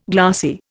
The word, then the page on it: glassy: Indian